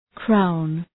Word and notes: crown: kraʋn

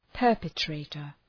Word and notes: perpetrator: ‘pɜ:rpə,treıtər

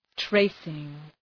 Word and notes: tracing: ‘treısıŋ

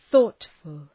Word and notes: thoughtful: ‘ɵɔ:tfəl

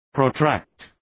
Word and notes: protract: prəʋ’trækt

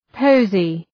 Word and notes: posy: ‘pəʋzı

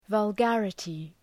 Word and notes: vulgarity: vəl’gærətı

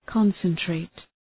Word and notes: concentrate: {‘kɒnsən,treıt}

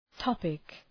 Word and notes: topic: ‘tɒpık

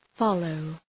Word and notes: follow: {‘fɒləʋ}

